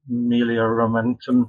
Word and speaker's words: Melioramentum: And another essay actually points out that the rites like the Consolamentum and the Melioramentum, is that right, Amanda? – Mm-hmm.